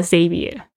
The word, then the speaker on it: Xavier: 我们之前有一个在高雄，我们有这个 WordPress 小聚的主办人，他叫那个，他也叫这个名字 Xavier, 但是呢他会叫 Xavier, 喜欢人家叫他 Xavier, 就是他把那个 X 的发音是 Z 的声音，好像也可以这样说。